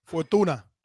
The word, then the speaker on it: Fortuna: I felt like Douglas really beat Fortune. I pronounced that right. – Fortuna.